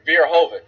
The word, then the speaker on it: Verhoeven: Verhoeven, yeah.